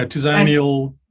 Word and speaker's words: Tizanael: I'm going to maybe say this wrong, but Tizanael? – Yeah, Tizanael. – Tizanael. – Tizanael is how I would say it, but as with all my character names, you can say them however you like.